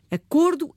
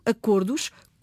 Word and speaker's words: acordos: Quando passou a funcionar como nome, o O passou a ser a vogal da sílaba tónica e ficou fechado. No plural, essa pronúncia mantém-se fechada. Portanto, devemos dizer acordo, acordos, como lobo, lobos.